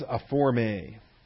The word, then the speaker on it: aphorme: So the vocabulary there is aforme. Aforme. A-P-H-O-R-M-E. It's the long E, the Ada.